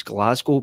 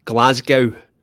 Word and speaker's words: Glasgow: Well, the first thing that I picked up there was you're one of the few Americans that pronounces Glasgow by its proper name. – Oh, what's the other way to say it? – Glasgow.